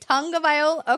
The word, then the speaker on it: Tagovailoa: When I interviewed him at the Super Bowl, he corrected me on how to pronounce his name, which I appreciated, though. I was like, okay, wait, Tagovailoa? Okay.